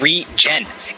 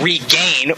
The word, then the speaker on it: Regen: It's pronounced regen, as in short for the word regenerate. – Not Regan. – Not regain, which is actually another spell.